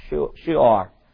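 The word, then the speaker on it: Shur: Now, Shoar looks like shore, but it's pronounced S-H-O-O, long O, and then slash O-R, Shoar. Now, Shoar is in between Palestine or Canaan land and Egypt.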